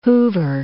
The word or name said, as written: hoover